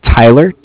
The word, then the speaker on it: Tyler: My name is Tyler, T-Y-L-E-R, and I'm calling with Cardiff. It's spelled C-A-R-D-I-F-F, as in Frank.